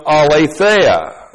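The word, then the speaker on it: aletheia (ἀλήθεια): A-L, long E-T-H, long E-S. Alethes. Now, this is an adjective, and this adjective is related to the noun aletheia. And a lot of you are familiar with this noun in our studies. A-L, long E-T-H-E-I-A.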